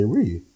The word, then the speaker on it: Ryu: So yeah, just pretty interested to know that, you know, the locals say Ryu.